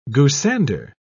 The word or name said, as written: goosander